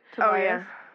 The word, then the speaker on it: Tobias: Oh, Tobias Menzies, I think is how you say it. – Tobias.